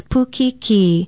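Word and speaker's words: pukikī: Pukiki is spelled P-U-K-I-K-I with a kahako or stress mark over the last vowel sound.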